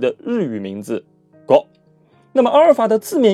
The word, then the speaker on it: Go: Go 其实正确的发音应该是 Go，来自日语啊，就是围棋的意思。日语中围棋叫做 Ego，简称叫 Go。